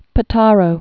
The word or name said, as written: Potaro